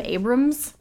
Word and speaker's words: Abrahams: Directed by his name is spelled like Jim Abrahams, but I feel like I've heard Jim Abrams as a name before. – That's right.